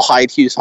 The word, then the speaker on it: Heidhues: My last name is spelled H-E-I-D. H-U-E-S. I am the only Paul Hyde Hughes on LinkedIn, because it's a nice, unique last name.